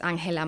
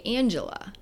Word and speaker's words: Angela: You know, my full name is Angela Maria Vergara, so very much Hispanic sounding. And then learning to say Angela and like pronounce Angela.